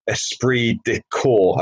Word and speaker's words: esprit de corps: And you use particularly the term esprit de corps. Have I pronounced that correctly? – Absolutely, yeah. You taught that from day one of joining the Marines, and they create this esprit de corps.